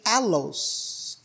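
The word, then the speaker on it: allos (ἄλλος): That word, another, is Greek pronunciation allos, A-L-L-O-S.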